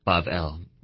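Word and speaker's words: Bavel (בבל): You look at Babel, or Bavel, pronounced in Hebrew.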